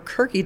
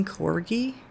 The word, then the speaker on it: corgi: And I finally just decided that it's people mispronouncing corgi and thinking it's Kirky instead of corgi.